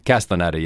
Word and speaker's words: Castellaneta: Am I saying his name right? – Castellaneta, yeah, from The Simpsons, yes.